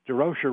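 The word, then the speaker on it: Durocher: I mean, when he gets there, somebody looks at Leo and said, ask him if he's Jewish, and Leo said no, but he said DeRocher rhymes with kosher.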